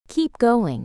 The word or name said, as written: keep going